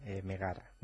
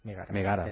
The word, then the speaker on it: Megara: Megara. – Megara. – Era la esposa de Heracles. Que no Megan.